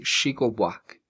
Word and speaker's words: Chico Buarque: Chico, se pronuncia así, Chico Buac, que es eh, Chico Buac Yolanda, así se pronuncia.